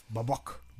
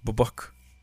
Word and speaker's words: Babak: Maar je hebt ook mensen zeggen babak. maar dat zijn die antis. Dat zijn die antis. Of babak. – Babak. – Ja, dus nee, hoe spreek je dat officieel uit? – Babak. Babak. Ja, bijna goed. Babak. Babak. – Zie je? – Dat is ja. toch het verschil, toch? – Is een dikke verschil, man.